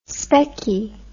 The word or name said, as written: specky